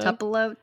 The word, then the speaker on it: Tupelo: Like, to Pilo? How do you say it? To Pilo? – Tupelo? Tupelo? – To Pilo.